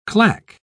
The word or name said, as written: clack